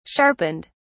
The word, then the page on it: sharpened: British English